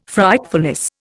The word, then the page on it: Frightfulness: Indian